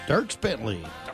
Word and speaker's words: Dierks Bentley: Dierks Bentley.